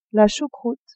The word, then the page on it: choucroute: female